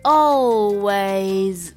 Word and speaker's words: always: OK， w 的时候嘴巴也稍微要撅起来一点点，w。 a way，然后是一个 z 的一个发音，always always， OK，学会没有？always， OK，一定要记住一下啊。